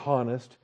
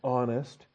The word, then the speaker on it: honest: You don't say honest, you say honest. And when you say honest, you've just closed your throat at the start of the syllable.